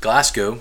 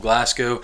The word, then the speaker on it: Glasgow: But then when I was 8 years old, our family moved to southern Kentucky, south central Kentucky, a town called Glasgow, Kentucky, named after Glasgow, Scotland. But we pronounce it Glasgow because we are from Kentucky.